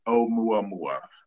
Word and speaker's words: Oumuamua: We're going to jump into doing a reading around what was Oumuamua, and that's how we pronounce it, Oumuamua.